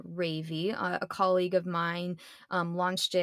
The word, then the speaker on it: Rayvi: And she, R-A-Y-V-I. Yes, that's how you pronounce it.